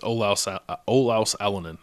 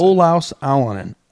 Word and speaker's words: Olaus Alanen: Okay, well, I know who my top two are going to be, but Olas Elenin, how do you say his name? – I think it's Olaus Alanen, I think is how you say it. Olaus Alanen.